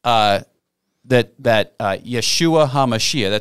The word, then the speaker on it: Yeshua HaMashiach: The message that Jesus came, the message that Yeshua HaMashiach, that's Jesus the Messiah. Okay, that's how they say it in Hebrew.